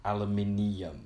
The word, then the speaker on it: aluminium: This says, although Americans and Canadians spell and pronounce the name aluminum without the I, while the British and, in parentheses, in most rest of the world, use the spelling and pronunciation of aluminium.